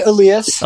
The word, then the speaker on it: Elias: It's actually Elias.